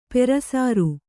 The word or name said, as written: ಪುರಃಸರ